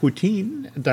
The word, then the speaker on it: poutine: They also have a beef short rib poutine.